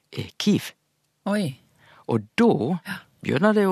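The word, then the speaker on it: Kyiv: Men ukrainsk altså Kiev. Det er svaret.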